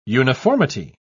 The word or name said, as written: uniformity